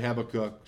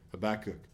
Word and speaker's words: Habakkuk: Some say Habakkuk, some say Habakkuk. Depends how you want to pronounce it. Both are okay.